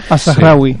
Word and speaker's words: Al-Zahrawi: Al-Zahrawi.